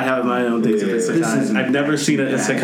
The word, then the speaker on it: Isekai: Isekai, yeah.